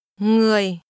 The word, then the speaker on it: Người: It begins with an NG combination. – Người. Người. Người. Người. – This is pronounced with a low, falling tone.